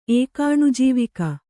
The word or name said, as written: ಏಕಾಣುಜೀವಿಕ